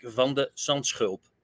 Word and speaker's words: van de Zandschulp: Well, if you want to pronounce it in the Dutch way, it's Botic van der Zandschulp. So it's a sch. That's the difficult part.